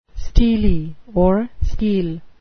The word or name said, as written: stele